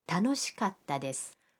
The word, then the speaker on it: 楽しかったです: 楽しいです는 楽しかったです. – 즐거웠어요가 됩니다. – 갔다는 카와 타 사이에 한 박자를 넣어야 합니다.